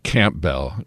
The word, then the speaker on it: Campbell: We do know a little bit about the translator, a man by the name of Robert Campbell, or as my wife's family pronounces it, Campbell.